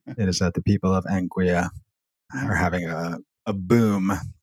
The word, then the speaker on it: Anguilla: The small Caribbean territory of Anguilla. Or is it pronounced Anguilla? A-N-G-U-I-L-L, Anguilla.